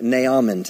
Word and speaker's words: Naaman: Why do you say Naaman? Well, it's N A A M A N, so. Naaman. And by the way, I, Brother Copeland pronounces it that way.